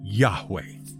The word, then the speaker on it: Yahweh: However, most people agree to work with the pronunciation of Yahweh when the tetragrammaton is encountered. Unless, of course, you decide that those letters weren't pronounced that way and you want to do things your own way and so went with a more literal Yehovah which, thanks to Latinization, became Jehovah, which is almost certainly entirely wrong, even considering that no one is really sure what it should be in the first place.